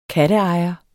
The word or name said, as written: katteejer